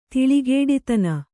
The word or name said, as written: ತಿಳಿಗೇಡಿತನ